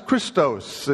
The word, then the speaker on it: Christos: The Messiah is Christos, and Christos is the word that stands for Christ.